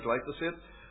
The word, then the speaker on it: glyphosate: 18 is the use of weed killer glyphosate.